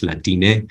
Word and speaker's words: Latine: And similarly, Latine, with an E on the end, pronounced Latine, it's also an inclusive term supporting that trans, gender nonconforming people.